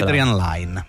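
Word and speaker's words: Adrian Lyne: Come si pronuncia? – Adrian Lane. – Line, questo lo dici tu? – Lo dico io.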